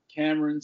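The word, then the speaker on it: Camron: You can find me everywhere on social media at Cameron Smith. No E in Cameron. Again, as I always like to say, Cameron, the rapper, made my name cool.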